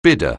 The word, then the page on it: bidder: anglais